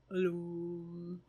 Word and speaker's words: lam: And its mantra is, it's spelled L-A-M, but when you do it, it's Loom.